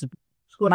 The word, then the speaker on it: Scotchgard: Scotchgard. Did I say it wrong? – No. It's just Scotchgard.